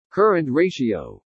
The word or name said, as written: CURRENT RATIO